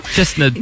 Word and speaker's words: Chestnut: Chestnut. Un peu comme les nuts, les noisettes, ouais.